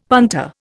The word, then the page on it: punter: Indian